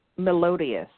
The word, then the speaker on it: melodious: Meloudious. Do you remember how you pronounce that word? – Melodious. – Melodious, thank you.